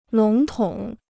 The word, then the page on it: 笼统: lǒng tǒng